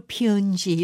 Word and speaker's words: 편지: 그러니까 그리고 예전에는 이제 편지를 좀 장음으로 피은지 이렇게 발음하시는 분들이 많았거든요.